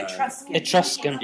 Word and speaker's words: Etruscan: Last month, an untouched Eucretian tomb. Did I say that right? Eucretian. – No. – Etruscan. – Yeah, I can't say that word.